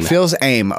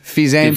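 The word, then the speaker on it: Fils-Aimé: COO, the coup, Reggie how do you say this? – Phil's aim. – Okay. Is retiring. Fils aim. – If you're French.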